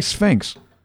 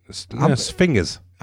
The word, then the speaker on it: sphinges: What's the plural of sphinx? – I'm gonna say sphinx. – Wrong. Sphingas. – Okay, that's good. – Yeah. – How spell that? – It's sphinx with a G instead of an X. But yes, G-E-S without an X. – Well, is that sphinges? – Sphinges.